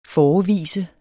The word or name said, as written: FOREVIS